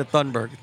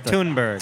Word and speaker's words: Thunberg: Global warming is not going to be affected by Greta Thunberg. How do you pronounce the last name? – I've heard Thunberg. I've heard Thunberg. – Thunberg. That sounds better.